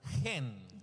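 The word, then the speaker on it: hen (חן): Es la palabra chen. – The word is chen. – Que se pronuncia hen.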